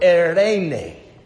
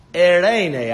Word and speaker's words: Eirene: No, peace that we've translated in the Greek was actually pronounced the word Eirene. I did the best trill of my tongue that I can. Eirene, I'll do it again.